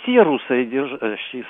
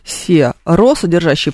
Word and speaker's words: серосодержащие: И две партии академиков, там, ну, людей, так сказать, грамотных, образованных, доказывали друг другу, как нужно правильно говорить, серосодержащие соединения или серусодержащие соединения. И вот, насколько я знаю, они так и ни до чего не договорились. – Спасибо. Ну, вообще, конечно же, точно совершенно серосодержащие, потому что у нас соединительных гласных бывает только две.